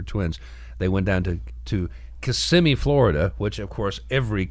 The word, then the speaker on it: Kissimmee: It's pronounced Kissimmee."